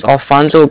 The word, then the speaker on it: Alfonso: My name's Alfonso Garza. It's spelled A-L-F-O-N-S-O. Mm-hmm. – My last name's Garza. It's G-A-R-Z-A.